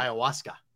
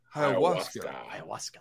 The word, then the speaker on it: ayahuasca: Ayahuasca. Ayahuasca.